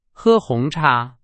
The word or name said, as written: 红茶